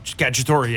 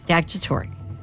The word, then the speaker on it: Cacciatore: Are we saying it right with the E on the end? – Yeah. Cacciatore, yeah. – Cacciatore.